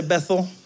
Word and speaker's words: Bethel: Can you say Bethel? Bethel. Now, that's the English pronunciation in the Hebrew. Two words, Beit El, house of God.